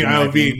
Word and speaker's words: Iovine: Iovine? – Jimmy Iovine.